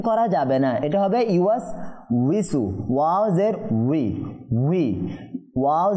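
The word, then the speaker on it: ইউয়াস উইসু (يُوَسْوِسُ): ইউয়াস উইসু। – আচ্ছা, এই শব্দটাকে অনেকে আমি শুনেছি ইউয়াস বিসু পরে, ইউয়াস বিসু, এখানে দেখেন ইউয়াস বিসু যদি কেউ পরে, ওয়াও জের উই,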